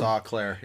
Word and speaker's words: Eau Claire: Is it Eau, E-A-U, Eau Claire? I think it's pronounced Eau Claire. Yeah. – What is it, Grandpa Blackhawk? E-A-U, Eau Claire.